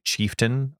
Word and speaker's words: chieftain: That's pronounced like chieftain, but it's spelt chieftain, just like mountain. – I feel like almost all words with T-A-I-N are tin.